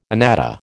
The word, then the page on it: anatta: American English